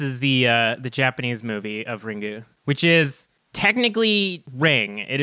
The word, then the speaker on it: Ringu: But the way that Japanese is written, you can't just have you have to have either a vowel or an N sound at the end of a word. So based on writing Ring in hiragana, it's pronounced, or katakana, it's pronounced Ringu.